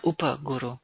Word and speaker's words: Upa Guru: And I'm so grateful for you talking about the Upa Guru. Is that how you say it? How do you Upa, the Upa Guru. Yeah, Upa Guru.